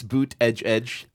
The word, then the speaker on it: Buttigieg: Buttigieg. – Buttigieg. – He claimed it's boot edge edge. – But the emphasis is on the boot. It's boot edge edge. – We're going with Buttigieg.